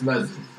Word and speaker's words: Vezev: I can't help but notice that Vezev, was it? Vezev? Am I pronouncing that correctly? – Yes, Vezev. Thank you.